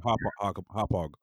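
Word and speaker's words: Hauppauge: It's based in Long Island, in I believe the town is called Hopog. I hope I'm saying that right. – Yeah, Hopog. – Yes, Hopog, Long Island.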